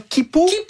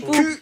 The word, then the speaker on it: Quipu: Et si vous pensiez que c'était déjà vertigineux d'imaginer l'Anéakea, attendez qu'on vous parle de comment Kipu, ça s'écrit Kipu. Q-U-I-P-U.